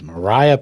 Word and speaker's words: Maria: I know when I first started here, I was corrected often because it's not Maria, it's not Parham. I'm trying to think of all the other different ways. It's Mariah Parham.